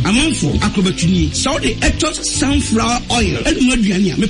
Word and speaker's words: Aetos: A-E-T-O-S. Etos. – Etos, correct.